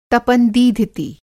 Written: तपन-दीधिति

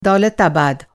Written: दौलताबाद

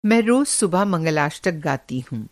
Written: मंगलाष्टक

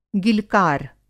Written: गिलकार

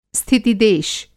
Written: स्थितिदेश

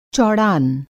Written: चौड़ान

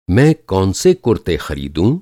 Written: कौन सा

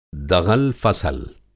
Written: दग़ल-फ़सल

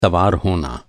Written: सवार

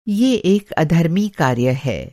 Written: अधर्मी

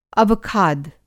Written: अवखाद

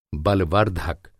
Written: बलवर्धक

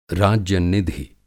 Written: राज्य-निधि

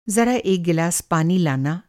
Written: ज़रा

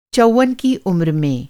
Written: चौवन

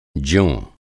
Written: ज्यों

